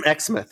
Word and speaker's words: Exmouth: Yeah, Exmouth.